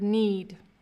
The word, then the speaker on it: knead: It sounds exactly like the word need.